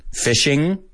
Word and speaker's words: fishing: Fishing, fishing. Pero los americanos lo pronunciamos con sin la G. Fishing.